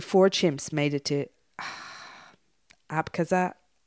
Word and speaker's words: Abkhazia: Yeah, I'd say Abkhazia is how you say that.